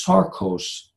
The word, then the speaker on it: sarkos: I did some work on looking up that Greek word, and then the Greek word is sarkos, S-A-R-K-O-S. That's the way it's pronounced.